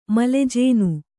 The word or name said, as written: ಮಲೆಜೇನು